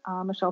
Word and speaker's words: Michel: Sign up for my newsletter, Michelle Prince Books on Facebook and Instagram.